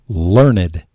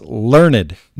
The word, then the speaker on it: learned: And pretty soon you're going to become what they call learned, which is spelled just like learned, but it's learned.